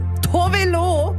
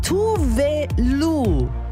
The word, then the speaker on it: Tove Lo: Die Sängerin Tove Lo, dachte ich bisher. Und auch Nigisa Blumenstein aus dem Kosmo-Musikteam dachte das. – Mein Leben ist eine Lüge. So krass. – Nein. Tove Lo, so wird sie nämlich ausgesprochen. Korrektur also, 12 Jahre nach dem Hit.